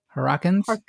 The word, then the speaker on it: Harkonnen: It is revealed that Harrakinus, how do you say it? Harkonnens.